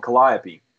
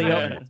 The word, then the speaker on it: Calliope: She's gonna get me because I'm gonna say it wrong. Calliope. – No, no you, said, said it right. no, right. You said it wrong. You said it exactly Calliope.